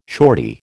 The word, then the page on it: shortie: American English